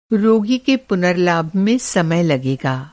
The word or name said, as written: पुनर्लाभ